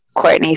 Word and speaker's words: Cortni: Well, so you can follow me on all of my social media at Superstar Courtney. My name is spelled C-O-R-T-N-I. It's actually not C-O-U-R-T-N-I. I need to actually correct that on Facebook. But it is Superstar, the regular spelling, Courtney, C-O-R-T-N-I.